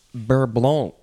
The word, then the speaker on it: beurre blanc: The first word is beurre blanc. Beurre blanc. I don't think you should say the C sound at the end. It is spelled B-E-U-R-R-E. Next word, B-L-A-N-C.